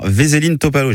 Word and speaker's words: Veselin Topalov: Jeudi matin à Nîmes, un ancien champion du monde, le Bulgare Veselin Topalov.